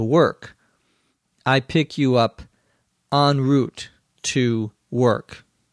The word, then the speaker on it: en route: En route, which is sometimes pronounced en route, although the preferred pronunciation is en route or en route, is a French expression.